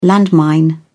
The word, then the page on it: landmine: British English